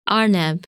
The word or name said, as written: Arneb